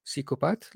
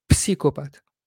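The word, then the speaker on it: psychopathe: صلح لي اذا انا غلطان حرف البي في بدايه كلمه سيكوبات لا تلفظ فتبقى سيكوبات وليست بسيكوبات لا يقال بسيكوبات سيكوبات فقط أي.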